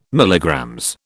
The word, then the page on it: MG: British English